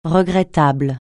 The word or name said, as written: regrettable